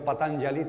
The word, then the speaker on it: Patanjali: Y la pronunciación Patanjali tiene detrás un nombre que escrito en castellano es Patanjali.